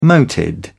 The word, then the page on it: moated: British English